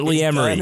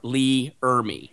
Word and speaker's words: R. Lee Ermey: Gunnery Sergeant Hartman from Full Metal Jacket is it's not Lee Emery. – Gunnery Sergeant R. Lee Ermey.